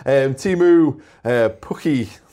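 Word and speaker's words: Teemu Pukki: Timu Pukki.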